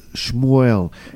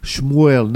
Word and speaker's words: Shmuel: Samuel, in Hebrew, is Shmuel. Okay. In other words, instead of S-A, you have S-H.